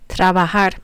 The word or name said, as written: trabajar